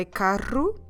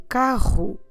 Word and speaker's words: carro: If you say carro, there's nothing wrong about it. What we want to hear is a vibration when you pronounce it the r or the r. So whether you're saying o carro or if you're saying o carro, or even if you're vibrating more the palate, carro, there, the three of them are okay.